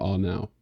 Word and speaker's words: Arnau: Like, you've got to roll the R for Arnau.